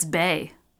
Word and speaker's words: Baies: Okay, I just looked it up. It's Bay.